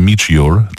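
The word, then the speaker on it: Midge Ure: no różnie wymawia się to nazwisko, ale Mitch Ure. To zdecydowanie najbardziej poprawna forma, bo wynikająca z fonetyki języka angielskiego.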